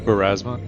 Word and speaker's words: Pharasma: Okay, so you're gonna target the end of the festival when he's on stage talking about the festival itself and the diasma, that the god, did I say that right? – Pharasma. – Pharasma, sorry. Yeah, where he's talking about Pharasma and, you know, the cycle of souls.